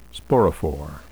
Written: sporophore

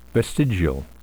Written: vestigial

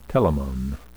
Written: telamon

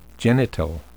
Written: genital

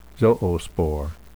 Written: zoospore

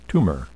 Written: tumor

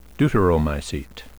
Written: deuteromycete

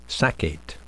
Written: saccate

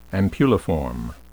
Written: ampulliform

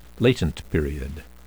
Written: latent period

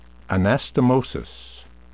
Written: anastamosis

